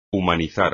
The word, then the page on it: humanizar: espagnol